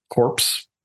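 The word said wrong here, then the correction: corps: How do you say that? C-O-R-P. – Like, the core. – Core. Thank you.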